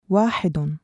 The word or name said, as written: وَاحِدٌ